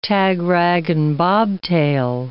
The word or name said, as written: tagrag and bobtail